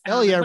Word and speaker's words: Elia: Elia rhymes with hell yeah.